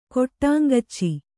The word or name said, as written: ಕೊಟ್ಟಾಂಗಚ್ಚಿ